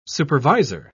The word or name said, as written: supervisor